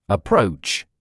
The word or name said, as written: approach